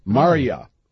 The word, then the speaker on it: Marja: Marja. – Marja. But it's spelled, again, M-A-R-J-A, so you gotta ask.